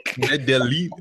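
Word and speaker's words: Medellín: No, it's not Medallion. Don't be an American. – William thinks he can pronounce everything, doesn't he? – What's it called? Medellin.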